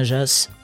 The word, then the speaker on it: Jas: Cela vous dirait une petite balade, une randonnée dans le Ventoux d'un Jas à l'autre, véritable immersion dans l'histoire du Ventoux. Jas, ça s'écrit J-A-S, mais en provençal, ça se prononce Jas.